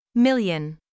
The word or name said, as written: million